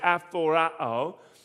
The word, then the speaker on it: aphorao: But there's how you'd pronounce it, aphorao.